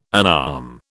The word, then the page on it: unarm: British English